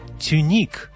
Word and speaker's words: тюник: Но знаток балета скажет «тюник» и только «тюник».